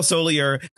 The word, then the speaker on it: Soller: Played by played by Kyle Soller. Soller, I think his last name is pronounced.